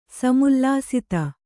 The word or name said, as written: ಸಮುಲ್ಲಸಿತ